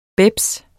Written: bebs

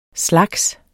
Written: slags

